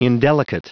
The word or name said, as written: indelicate